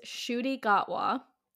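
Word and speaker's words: Ncuti Gatwa: My crush grape, his name is Shuti Gatwa, if I'm pronouncing that right.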